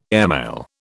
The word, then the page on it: airmail: British English